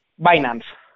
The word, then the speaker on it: Binance: ¿Lo he dicho bien? ¿Binance o Binance? Binance. Binance. Eh, ¿Cómo nace? – Bueno, Binance nace en 2017.